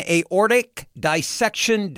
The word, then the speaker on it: aortic dissection: aortic dissection diagnosis.